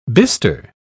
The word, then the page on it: bistre: British English